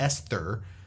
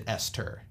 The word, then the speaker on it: Esther: So Esther Perel's podcast, and it's spelled Esther, but she pronounces it Esther.